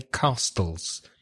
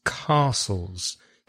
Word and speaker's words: castles: One thing perhaps before we speak about castles is the pronunciation of the word castles, because it has a silent T. And I hear some of my students say castles. No, it's castles. C-A-S-T-L-E-S.